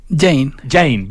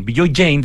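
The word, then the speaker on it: Bijoy Jain: Tú hablaste del trabajo que está haciendo Villoyain, ¿así está bien pronunciado, no? ¿O Jain? – Jain, Villoyain, de Estudio Mumbai, un arquitecto de reputación mundial para Chimbarongo.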